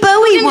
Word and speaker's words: Bowie: Can I just say well done for pronouncing David Bowie correctly, by the way? I really judge people on that.